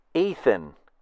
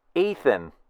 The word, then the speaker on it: Ethan: Actually, it's pronounced Ethan. You hear the difference? Ethan.